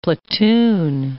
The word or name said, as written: platoon